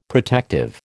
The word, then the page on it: protective: American English